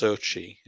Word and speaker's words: Xochitl: Uh, but I think she, it's her name is um, pronounced differently to how it's spelt. Um, it's pronounced Sochi, yeah, her first name, Xochitl Gomez.